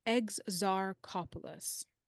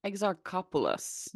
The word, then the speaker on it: Exarchopoulos: How do you pronounce it? – So I actually went on YouTube, like how to pronounce her name. – Yeah. – They gave me Exarchopoulos. – Exarchopoulos. – Yes.